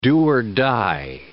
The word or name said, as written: do-or-die